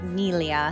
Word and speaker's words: Ventimiglia: Milo Ventimiglia. Is it Ventimiglia or Ventimiglia? You speak Italian. – I mean, in Italian, you would pronounce that Miglia.